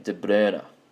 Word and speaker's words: De Bruyne: Apparently you pronounce his name De Bruyne. That's how you pronounce it, but I'll just say De Bruyne.